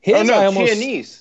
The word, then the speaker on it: Chianese: Carl Chianese, which I think that's how you pronounce it. – Chianese. That's Dominic Chianese. He plays Uncle Junior in Sopranos, which I know. – Oh, I think you're right. Chianese. – Yeah, that's how I know how to pronounce it.